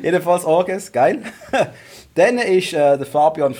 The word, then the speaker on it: Orges: Ich glaube, er heisst Orges. – Orges? – Tönt auch. Tönt. Gut, Orges. – Ja, Orges.